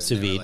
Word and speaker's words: sous vide: Sous vide.